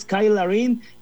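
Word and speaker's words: Cyle Larin: se escribe Cyle, C-Y-L-E, pero como su mamá le decía a Kyle, él dice, a mí me van a decir todos Kyle, entonces es Kyle Larin el que metió el gol con el que Canadá empató, y con eso, bueno, pues este grupo ya abrió hostilidades, Carlos, amigos.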